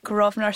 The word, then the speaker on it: Grosvenor: Grosvenor Cinema on Ashton Lane.